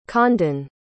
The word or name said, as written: condan